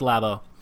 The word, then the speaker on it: Labo: All right, we'll go with Labo. – Like a laboratory. – Oh, that makes me more sense. Okay, we're going to go with Labo.